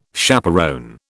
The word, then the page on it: chaperon: British English